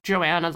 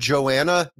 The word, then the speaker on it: goanna: It's spelled with a G. It's G-O-A-N-N-A.